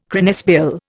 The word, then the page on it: cranesbill: Indian